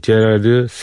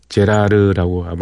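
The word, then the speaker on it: Gérard: 그리고 특히 이, 제라드 세인트 폴, 제라드 세인트 폴이라고 읽어야 되는데 이제 프랑스 말이니까 제라르라고 아마 읽을 겁니다.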